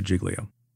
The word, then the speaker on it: Giglio: Lots of people don't pronounce it that way. They pronounce it Giglio. In the Southern District of New York, for right or wrong, we always pronounce it Giglio.